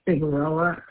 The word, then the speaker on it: Figueroa: S-A-R-A-I. – Okay. And last name? – Figueroa. F-I-G-U-E-R-O-A.